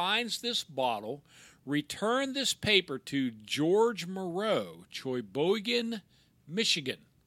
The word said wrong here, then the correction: Sheboygan: I think and, it's Sheboygan. And tell where it was found. How do you say it? Sheboygan. Sheboygan, okay and it was dated November 1926.